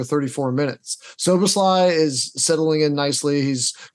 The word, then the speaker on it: Szoboszlai: Szoboszlai.